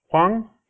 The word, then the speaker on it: Hwang: Huang. – Huang. – H-W-A-N-G. – Okay, yes. So the H is silent.